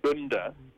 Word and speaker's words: Gunda: And I'm not even sure how to pronounce his title, but I think it's Gunda. – Okay. – It's G-U-N-D-A.